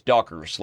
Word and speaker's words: Doggers: It's pronounced, by the way, he's Dutch, so it's Dockers, like Dockers pants.